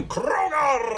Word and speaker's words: Krogar: You gotta roll your R's like this, the creature from Krogar!